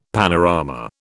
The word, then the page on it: panorama: British English